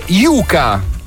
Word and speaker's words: Yuka: Anche solo sbagliando l'accento, ecco, che andrebbe messo sulla prima vocale. – Mi raccomando, La U è la prima vocale e l'app di cui stiamo parlando è Yuka.